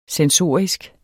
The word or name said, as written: sensorisk